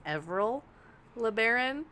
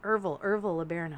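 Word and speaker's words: Ervil LeBaron: said it in my head as Everil LeBaron. And so listening to her, she kept saying Irval LeBaron. I was like, she's saying it wrong. And then I looked at him, I was like, oh, holy shit, nope, I'm dyslexic.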